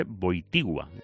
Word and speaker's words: Wojtyła: Carlos José Wojtyła,